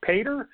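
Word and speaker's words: Petr: I think it's pronounced Pater.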